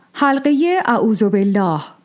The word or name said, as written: Aᶜūdh-u billāh